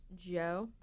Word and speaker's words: Djo: D-J-O, but it's just pronounced Joe.